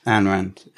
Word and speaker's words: Ayn Rand: I might pronounce some people here wrong, but I've got, is it Anya Rand? – Ayn Rand. – Ayn Rand. Yeah. Ayn Rand, there you go.